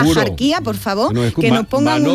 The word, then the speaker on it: Axarquía: Y aunque hoy la RAE deja claro, la RAE dice que lo culto y correcto es pronunciarlo con J, es decir, asarquía, la tradición local sigue pronunciando y no solo escribiendo asarquía o asarquía, ¿no? Con una H aspirada, con una, sí, una H aspirada.